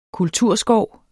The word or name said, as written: kulturskov